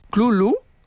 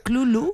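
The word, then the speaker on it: Cthulhu: Por eso que la pronunciación más aceptada fonéticamente es Clulu, ¿vale? – Clulu.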